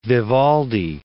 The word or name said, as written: Vivaldi